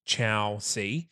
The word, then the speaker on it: Chausie: So Chowsy, as you, is spelt C-H-A-U-S-I-E. And pronounced Chow C.